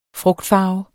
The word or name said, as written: frugtfarve